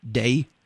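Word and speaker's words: day (δεῖ): in particular focusing on a little Greek word, is pronounced day.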